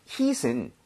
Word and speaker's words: heat: 그리고 발음 주의하셔야 되는 게 heat은 이렇게 치다 할 때 H-I-T죠. hit. – 아, 그건 다르죠? – 다릅니다. – 네. – H-E-A-T는 중간에 모음이 두 개 끼면서 입모양이 좀 벌려져야 돼요.